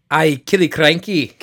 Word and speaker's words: Killiecrankie: Actually, if it's Scottish, you're probably pronouncing it 100% correctly. – No, I think... – Killiecrankie. – Killiecrankie probably sounds right. But it looks like Killer Cranky.